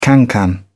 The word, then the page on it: cancan: British English